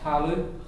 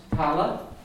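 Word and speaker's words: talamh: It's an Ulster sound, it's a short U sound, talu. Talu. In Connemara, it's tala, and in Munster, it's taluf. But we'll stick the Ulster for the time being. Talu, I-M-H at the end of the word.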